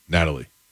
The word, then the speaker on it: Nathalie: According to court documents, the Miami-Dade County, How would you say it? N-A-T-H-A-L-I-E. – Natalie. – That's Natalie? – Yep. – With an H in there? – Yep, it's silent.